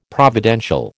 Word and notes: providential: American English

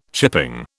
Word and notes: chipping: British English

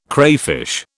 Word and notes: crayfish: British English

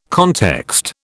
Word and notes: context: British English

